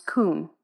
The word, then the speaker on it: kuwn: In Hebrew, the word is kun, and it's spelled K-U-W-N, and it's pronounced kun. And it means simply to be set up, to be established, to be fixed, or firmly established.